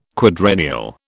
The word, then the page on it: quadrennial: British English